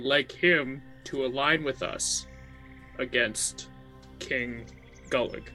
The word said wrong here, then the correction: Gullup: Gullup? Did I say that wrong? Gullup. – Gullup. – Exactly.